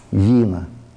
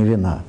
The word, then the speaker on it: Вина: И это уже будет закон смещения вина. Ударение на И, а не вина.